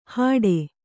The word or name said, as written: ಹಡೆ